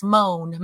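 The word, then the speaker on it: Moné: But it's ah, not. Monet. it's just moan. Mercedes moan. – yes. For the francophones, there's an accent aigu, so it's Monet.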